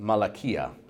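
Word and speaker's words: malakia (μαλακία): The Greek word for effeminacy in the New Testament is a word that's pronounced malakia, which means softness.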